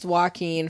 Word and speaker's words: Joaquin: Why do you always say his name? Joaquin. – Joaquin. – It's Joaquin Phoenix. It's not hard.